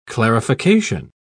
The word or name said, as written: clarification